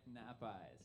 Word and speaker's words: Nap Eyes: You say it, it's like nap eyes, yeah. – The sea is silent. – Yeah, like two words and like as if you were sleeping.